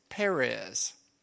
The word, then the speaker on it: Perez: Now, the one that the thread is around is Perez. That's how you say his name.